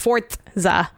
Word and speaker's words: Forza: We really, really want you to know it's pronounced Forza. Forza.